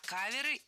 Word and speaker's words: кавер: И вот наши ребята, Ума Турман, там все будут музыканты играть ему кавера. И мы тоже решили сделать им подарок. – Не кавера и каверами, а каверы и каверами. Ударение в слове «кавер» всегда остается на первом слоге.